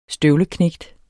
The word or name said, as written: støvleknægt